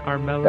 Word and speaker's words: Armello: Did I say that right, Trent? Armello? – That's completely right.